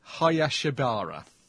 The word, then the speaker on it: Hayashibara: And Megumi Hayabashibara, a name always looks easy to pronounce written down than me actually trying to say it. Hayashibara.